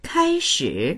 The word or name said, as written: kai1shi3